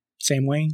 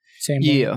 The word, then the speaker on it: Samhain: That's not at all the way you would think based on how it's spelled. Samhain? – Yeah, it's just spelled Samhain. So S-A-M-H-E-I-N.